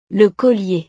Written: le collier

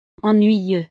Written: ennuyeux

